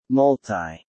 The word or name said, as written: multi